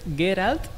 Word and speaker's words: Geralt: Pues se me acaban de corregir la pronunciación que es Geralt y es de Ribla.